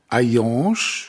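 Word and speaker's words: Hayange: Vielleicht zunächst der Ort, der heißt Ayange, geschrieben H-A-Y-A-N-G, das, so heißt der reale Ort. Der Ort im Roman heißt Ayange auch, aber geschrieben H-E-I-L-L und A-N-G-E. – A-N-G-E. Es spricht sich beides gleich aus.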